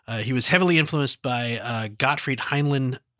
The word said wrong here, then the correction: Helnwein: I'm Hel, Hel, Helnwein. Helnwein. I can't ever pronounce that. Gottfried Helnwein. – Helnwein.